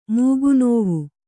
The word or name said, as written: ಮೂಗುನೋವು